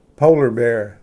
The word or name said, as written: ᏲᎾ ᎤᏁᎦ